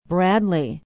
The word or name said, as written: bradley